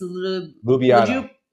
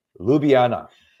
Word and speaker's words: Ljubljana: Ljubljana, Slovenia. Say it again. – Ljubljana.